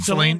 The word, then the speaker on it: Celine: Celine Dion.